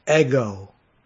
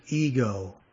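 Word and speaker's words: ego (ἐγώ): In the Greek text, the emphasis is on the first word, ego, or we would pronounce it ego, as in, let's talk about me now.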